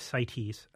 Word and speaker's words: CITES: What they wanted to do with bluefin was to include it under that convention, which is often pronounced CITES.